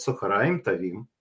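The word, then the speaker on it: цохораим тавим (צהריים טובים): ударение на «а», «цохораим тавим». Вот, я понял теперь. Ну да, видите, вот такой вот я израильтянин, косой, кривой, не могу... Ну как, вы же поправили меня? Ну вот «цохораим тавим», вот теперь я правильно говорю.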